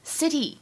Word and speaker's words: city: 而整个句子末尾的这个单词 city，如果大家练美式发音的话呢，当中会有一个美音浊化的现象，会发现这个 t 送气呢并不是那么明显，会读成 city。 city, city.